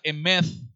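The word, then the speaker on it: emeth (אמת): viene de una palabra hebrea que se pronuncia emeth y que significa estabilidad, confiabilidad, verdad, certeza, fidelidad.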